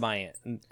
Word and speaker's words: Maians: The Mayans. M-A-I-A-N-S. Yeah, pronounced like the Mayans, like the Earth Mayans.